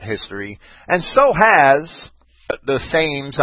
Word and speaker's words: Thames: On, uh, I was corrected tonight. It's the Thames River. I see an, a th and an a, and the English see a te. I, I, I guess it's their river. I'll pronounce it the way they do.